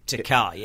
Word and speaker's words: Takei: it's not George Takei, it's George... Takei. It's George Takei, yeah. – Yeah. – Yeah. – Because it rhymes with... – Toupee, which is what he was saying.